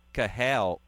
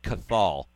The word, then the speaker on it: Cathal: and it looks like Cathal. I used to mispronounce it all the time, and I think a lot of people did. The T is actually silent in his name,